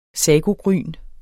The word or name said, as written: SAGOGRYN